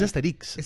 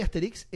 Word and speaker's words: Asterix: No, es Asterix. – ¿Es Asterix? – Sí, con el acento en la última sílaba.